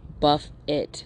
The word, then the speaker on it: buffet: Buffet, buff it, BUFFIT is how to pronounce it, but it means to pound or to beat against, to instill a sequence of blows.